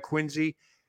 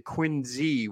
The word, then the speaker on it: Quincy: Don't forget, Quincy is spelled Q-U-I-N-C-Y, but the founder is Josiah Quincy purposely spells it or pronounces it Quincy with a Z. – Yep. – Q-U-I-N-Z-E-E. If you Google it, you'll see it. It's pronounced Quincy. So when somebody says Quincy, you know they're not from Quincy.